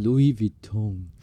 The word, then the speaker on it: Louis Vuitton: 对啊，因为我那时候我记得我应该是念 Louis Vuitton，很多人就会跟我解释，就是说、嗯、第一个 S 是不发音的，哦、真的吗？就是 Louis，然后呢念起来应该是 Vuitton。 – Vuitton，哎，你不觉得我自己知道我自己是乱念，所以我我念得很心虚啦。嗯，但你一发音变成是这样 Louis Vuitton。